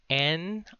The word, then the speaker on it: niya: So niya is spelled NIYA, niya.